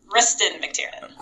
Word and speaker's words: Kristin Mctiernan: I said that right, Kristin? – Kristin Mctiernan. – Kristin Mctiernan. So I messed up a little bit.